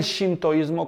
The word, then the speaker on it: sintoísmo: Vamos a ver entonces la introducción al tema del sintoísmo, o también el shintoísmo, como lo quieran pronunciar. Sintoísmo.